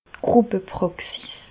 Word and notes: d' Gruppe-Pràxis: Bas Rhin; Reichshoffen

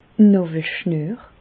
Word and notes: d' Nàwelschnüer: Bas-Rhin; Reichshoffen

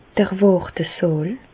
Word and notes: d'r Wàrtesààl: Bas Rhin; Reichshoffen

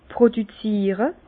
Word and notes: produziere: Bas Rhin; Herrlisheim

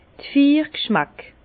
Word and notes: d' Vier g'schmäck: Bas-Rhin; Herrlisheim

